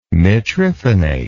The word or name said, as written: metrifonate